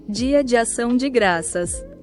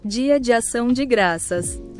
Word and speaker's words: Dia de Ação de Graças: Dia de Ação de Graças. – There we go. One more time for you guys. – Dia de Ação de Graças.